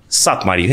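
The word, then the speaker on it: Szathmári: Este din fericire păstrată că are legătură și cu Eminescu, dar niciodată nu știu cum îi se, cum îi se pronunță numele pictorului ăsta, Carol Pop de... De Satmari. Satmari, He, mulțumesc frumos. Nu, că noi l-am românizat, că el e cu SZ, cu Y, cu și așa mai departe.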